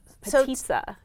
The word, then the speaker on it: potica: This has been really exciting to me to learn all of this new food, like petitza, for example. – Say that again? – Petitza, which is spelled P-O-T-I-C-A.